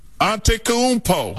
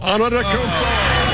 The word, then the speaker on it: Antetokounmpo: Giannis Antetokounmpo. – Antetokounmpo. – Antetokounmpo for three. – We're all cheering for Giannis Antetokounmpo. – They're long, they're athletic, they're versatile, and they're led by Giannis Antetokounmpo.